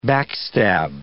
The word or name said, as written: backstab